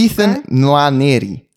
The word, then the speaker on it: Ethan Nwaneri: Ethan Nwaneri.